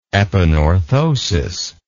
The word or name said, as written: epanorthosis